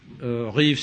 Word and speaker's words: Reeves: Reeves, c'est, la prononciation anglaise. En réalité, il y a un ou deux siècles, Monsieur Reeves s'appelait R-I-V-E-S,